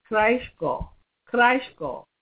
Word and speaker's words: Kraichgau: I found it's pronounced like that. It's Kraichgau, not Gau. It's spelled like a Gau, you know, the German Gaus, G-A-U, but I always pronounce it Gau, but I guess it's not pronounced Gau.